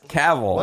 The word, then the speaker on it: Cavill: It's Cavill.